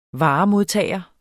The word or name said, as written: varemodtager